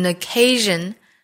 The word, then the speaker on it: occasion: occasion it gave us an occasion建议大家在读的时候呢先把语速降下来把音和音能够连在一起之后再去提加速度的事儿。It gave us an occasion. Occasion 这个词我们注意字母 S 对应的发音是 R, R, occasion, occasion.